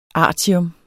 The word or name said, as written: artium